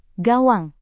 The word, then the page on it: gawang: Indonesian